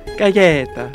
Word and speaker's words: Gaeta: It was a town called, I may mispronounce this, Gaeta? – Gaeta.